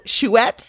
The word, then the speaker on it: Chouette: It's Chouette.